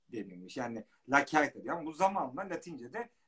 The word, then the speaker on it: lacerta: Sonra e, Latince lacerta diyor. Bir kertenkele yani e, Latince La Certa diye okunuyor.